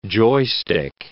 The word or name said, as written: joystick